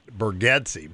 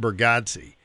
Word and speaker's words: Bargatze: Oh, you got to YouTube him, Nate, N-A-T-E. And his last name, I think they pronounce it Bergetze, but it's B-E-R-G-A-T-Z-E, Nate Bergetze.